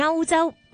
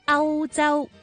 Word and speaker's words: 欧洲: 其他例子亦都包括欧洲的欧字，很多人会以为它是一个鼻音字，将它读成欧洲，但是其实正确的读音应该是零声母欧洲欧洲。